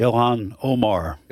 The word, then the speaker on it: Ilhan Omar: Ilhan, how do you pronounce it? Ilhan Omar.